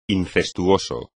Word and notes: incestuoso: espagnol

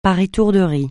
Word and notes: par étourderie: francais

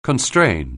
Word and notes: constrain: anglais

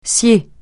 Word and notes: scier: francais

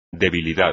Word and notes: debilidad: espagnol

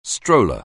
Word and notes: stroller: anglais

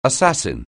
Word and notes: assassin: anglais

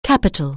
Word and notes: Capitol: anglais

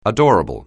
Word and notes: adorable: anglais

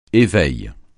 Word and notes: éveil: français

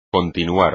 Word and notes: continuar: espagnol

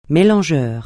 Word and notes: mélangeur: francais

